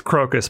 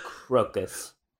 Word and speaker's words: Crocus: It's pronounced Crocus, by the way. – Oh, Crocus.